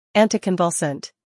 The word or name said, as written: Anticonvulsant